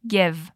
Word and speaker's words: give: Uma dica interessante de pronúncia aqui é sobre a palavra give. É fácil para gente brasileiro acabar falando give, mas na verdade a forma mais apropriada é give. Escuta de novo. Give. Give me.